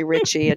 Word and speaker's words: Richey: but it's just in a Kimberly Richey. And just make sure you spell it R-I-C-H-E-Y.